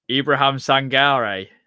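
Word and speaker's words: Ibrahim Sangaré: Ibrahim Sangare, as I've been told, that's how you say his name, from when you look, listen to PSV fans say it as well.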